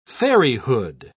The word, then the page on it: fairyhood: British English